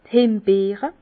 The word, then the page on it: d' Hembeere: pl.; Bas Rhin; Herrlisheim